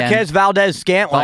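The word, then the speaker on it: Marquez Valdes-Scantling: Marquez Valdez-Scantling.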